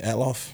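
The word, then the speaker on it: Althoff: I felt like the girl Bobby Atloff or whatever, I don't know if I'm saying her name right. That's how you say it? Atloff? – Mm-hmm.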